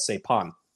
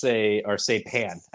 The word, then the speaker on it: Saipan: Someone sent me a whole care package from a country called Saipan. And I guess it's Saipan, actually. I was saying that wrong.